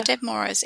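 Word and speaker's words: Ded Moroz: But it's D-E-D, so I take it you pronounce it dead? Dead Moroz? – Yeah. – Dead Moroz. M-O-R-O-Z, Moroz.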